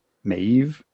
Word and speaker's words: Maeve: It's M-A-E-V-E. Maeve? – Maeve.